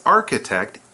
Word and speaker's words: architect: And Maggie says, a Japanese architect, Ito Toyo, designed it. Ooh, it's probably very interesting. – Okay, so Maggie 呢就说啊，其实我们就是在台中国家歌剧院，刚刚有提到吧，一位日本的建筑师的英文就是 architect. Notice the pronunciation of ch is sound like a k sound.